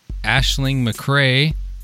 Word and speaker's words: Aisling McCrea: And we have Aisling McRae.